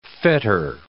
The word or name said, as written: fetter